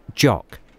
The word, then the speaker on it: Jok: Jock. – How do you spell it, please? – J-O-K.